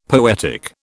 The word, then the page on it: poetic: British English